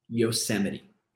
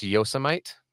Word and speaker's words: Yosemite: For me, it's kind of like saying, I'm going to Yosemite National Park. My wife and I just went there for a friend's wedding. – It's pronounced Yosemite.